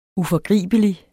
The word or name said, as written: uforgribelig